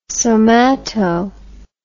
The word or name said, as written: somato